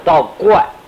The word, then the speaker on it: 道观: 这个在杭州啊有个秦皇山，杭州在凤城区啊，秦皇山上有个道观啊，道家的，不是佛家，佛家叫寺院，道家叫道观，看见那观看那个观，不等不读不念成观啊，观，道观，道观里头很大一个道家的丛林，秦皇山上面有个方丈道人，那这个道家也称方丈。